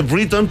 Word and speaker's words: Brereton: Sí, se dice Briton pero no, pues no le vamos a decir Briton ¿cierto? Hay que pronunciarlo como, como lo decimos en Chile.